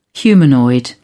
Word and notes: humanoid: British English

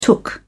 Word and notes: took: British English

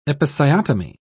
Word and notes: episiotomy: British English

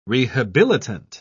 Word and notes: rehabilitant: British English

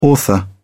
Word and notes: author: British English

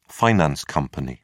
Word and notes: finance company: British English

